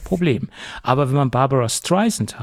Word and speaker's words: Streisand: weil Siri hat es bis vor ein paar Monaten mit Z ausgesprochen und nicht mit S wie Sand. Das heißt ja Barbara Streisand, wie der Sand am Strand.